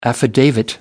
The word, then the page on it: affidavit: American English